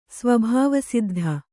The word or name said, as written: ಸ್ವಭಾವಸಿದ್ಧ